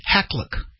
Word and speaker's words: Hakluyt: H-A-K-L-U-Y-T, Hackluck.